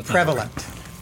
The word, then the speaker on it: prevalent: Prevailment. – Prevailment? – Yeah. No, prevalent.